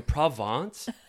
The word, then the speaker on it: Provence: Why is there a bunch of beautiful perfumes and stuff that looks like it's from Provence? Right? Is that how you pronounce it? – Oh, yeah. The south of France, baby.